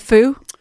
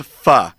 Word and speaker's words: pho: Do they have foo? – They have pho. – Pho. Pho. Sorry, I can't. – It's spelled pho. Now you're psyching yourself out, but it's pronounced pho.